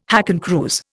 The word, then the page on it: hakenkreuz: Indian